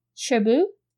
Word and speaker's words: Cherbourg: So the first one, and I am not expert in French like Katie, but I'm going to attempt to pronounce it like how they say in the movie, or at least what it sounded like phonetically to me. So The Umbrellas of Cherbourg. Cherbourg.